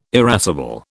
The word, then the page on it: irascible: British English